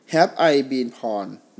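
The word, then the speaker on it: Have I Been Pwned: have i been porn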